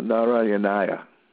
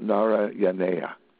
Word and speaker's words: Narayanaya: We have a mantra there, the Om Namo Narayanaya. Narayanaya. Om Namo Narayanaya. Or some pronounce it Om Namo Narayaneya.